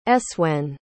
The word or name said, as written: eswen